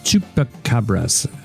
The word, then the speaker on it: chupacabras: So, chupacabra or chupacabras, you know, it's a Spanish pronunciation is chupacabras, which literally goat sucker. From chupar to suck, and cabra is goat.